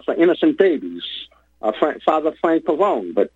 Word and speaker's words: Pavone: In Italian, you'd probably say Pavone, but he pronounced it Pavone. Frank Pavone.